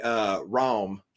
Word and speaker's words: Raum: And one year they gave away Raum, R-A-U-M. I think that's how you pronounce it.